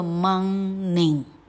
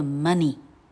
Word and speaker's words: money: 这个字不念作 morning，这个字念作 money。 money，吗？